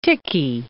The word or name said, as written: Tychy